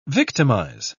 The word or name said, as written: victimize